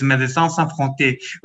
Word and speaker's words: Médecins Sans Frontières: I said Médecins Sans Frontières. – Well, my French is not the best, but it's Médecins Sans Frontières.